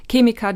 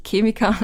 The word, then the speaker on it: Chemiker: die irgendwie Marken gegründet haben oder Chemiker, die dahinter sitzen. Ich bin Österreicherin, deswegen sage ich Chemiker, nicht Chemiker.